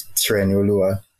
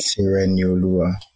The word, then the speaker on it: Tirenioluwa: If I'm pronouncing my name accurately, it's Tirenioluwa. So Tireni would be how you should pronounce it, but I go Tireni, T.